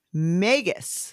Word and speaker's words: magus: So the singular form is magus, M-A-G-U-S, rhymes with Vegas.